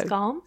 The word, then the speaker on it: Skam: And I'm probably going to say the title of it wrong because it's in Norwegian, but it's spelled S-K-A-M. – So, Skam? – Skam?